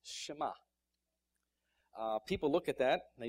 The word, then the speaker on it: Shema: The E is silent as they pronounce it, so it's Shema.